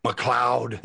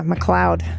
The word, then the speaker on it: MacLeod: Yeah, MacLeod. – Did you say MacLeod? – I did. I wasn't sure how to say it. – Well, anybody who's watched Highlander knows it's MacLeod. – It's because MacLeod.